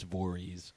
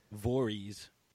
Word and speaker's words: Voorhees: Oh, that is not how he pronounces his name. According to his mother, it's Voorhees. – I think it's it depends on where you're from. – She says Voorhees.